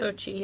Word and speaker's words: Sochi: is it Sochi? Is that how you say it? – Sochi, yeah.